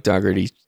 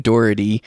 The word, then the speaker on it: Dougherty: Yeah, I mean, because like for example, in Mike Dougherty, I can never say his name, Doherty, Mike Doherty's Trick 'r Treat.